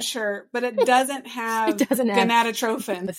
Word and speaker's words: gonadotropin: So that's the human chorionic gonadotropin.